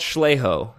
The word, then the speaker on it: Schleigho: But it's called Schleho, which was spelled... yep. It's spelled S-C-H-L-E-I-G-H-O.